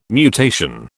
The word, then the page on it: mutation: British English